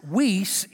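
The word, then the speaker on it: weis (υἱός): But then there is another word that's pronounced weis. It's spelled H-O-I-S-E, but it's pronounced weis, and it is...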